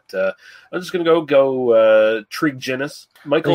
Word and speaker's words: Tregennis: Like, I hate to correct you, but it's a hard G, so it's Tregennis.